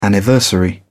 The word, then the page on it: anniversary: British English